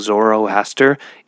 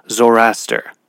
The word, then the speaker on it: Zoroaster: Everything I've watched and read about it, the way it's pronounced, is you kind of roll off that second O. Instead of saying Zoroaster, it's more like Zoroaster.